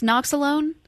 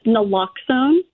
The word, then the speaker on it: naloxone: Now, what is um, is it pronounced noxalone? It's pronounced naloxone naloxone.